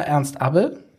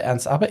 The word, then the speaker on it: Ernst Abbe: Dann sage ich mal Ernst-ABE. So, und alle in der schönen Stadt? – Hamburg. – Jena? – Ja. – Werden jetzt sagen, bist du nicht ganz dicht, der wird doch so ausgesprochen. Ich behaupte Ernst Abbe.